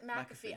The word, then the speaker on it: McAfee: So and before McPhee ran for presidency, also. – Yeah, McPhee. – McAfee. I can never say that right.